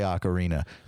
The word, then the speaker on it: Ocarina: I say Ocarina. You know why I say Ocarina? Because say fucking Macarena.